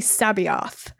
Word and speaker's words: C'Baoth: I think it was Sabaoth, uh-huh. which I might have butchered, but it was I had said it Kaboth, uh-huh. which for listeners who don't know Star Wars, it's C'Baoth. Yeah. So it's like it's really Sabaoth.